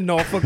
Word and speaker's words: Norfolk: I mean, look, I can count maybe on one hand how many times I've been to Norfolk, but, you know, I take pride in being able to say the name right.